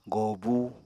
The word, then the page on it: gòobu: apatani